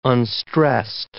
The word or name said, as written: unstressed